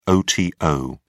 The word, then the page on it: oto: British English